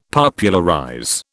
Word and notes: Popularize: British English

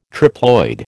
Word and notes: triploid: American English

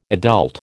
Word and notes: adult: American English

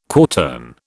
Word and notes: quartern: British English

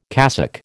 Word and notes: cassock: American English

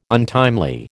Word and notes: untimely: American English

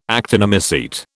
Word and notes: actinomycete: British English

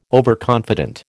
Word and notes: overconfident: American English

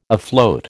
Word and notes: afloat: American English

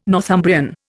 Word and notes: Northumbrian: Indian

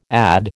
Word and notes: add: American English

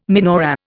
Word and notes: menorah: Indian

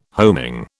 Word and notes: homing: British English

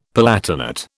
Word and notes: palatinate: British English

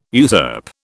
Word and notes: usurp: British English

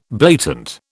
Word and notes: blatant: British English

